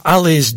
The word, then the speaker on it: Alys: The Man I Love is on Ali's Jazz, that's spelled A-L-Y-S, the Ali's Jazz label.